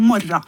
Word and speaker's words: morgen: Og så kan jo også Torgrim være litt sleip, og det at når han ser bokstavene M-O-R-G-E-N, så kan han godt uttale det som morra.